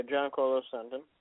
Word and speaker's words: Giancarlo Stanton: Yeah, Giancarlo Stanton.